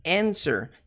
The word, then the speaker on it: answer: Que tem aqui o you, tem answer. O que significa you, você, vocês? Answer, answer. Preste atenção na pronúncia. Answer, answer, answer. Não é answer, é answer, sir, sir.